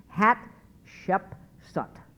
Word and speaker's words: Hatshepsut: Hatshepsut.